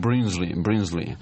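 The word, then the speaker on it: Brinsley: Бринли Шварц. Они некоторые. А некоторые говорят, это так зашифровано, чтобы никто не понял, как это на самом деле группа пишется. – Некоторые говорят Бринсли. Вот таким образом.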